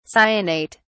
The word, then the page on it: cyanate: British English